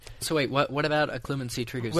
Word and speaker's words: Occlumency: How do you pronounce it? Occlumency? – Occlumency.